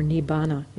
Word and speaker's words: nibbana: I'll read you a few of the Buddhist texts on this topic of nirvana, or nibbana is how it's pronounced in the Pali language.